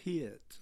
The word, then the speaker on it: Peart: Is that how you say it? – It is. – Peart. – Yeah. – Not Peart. – No. – Neil Peart.